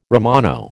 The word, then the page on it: Romano: American English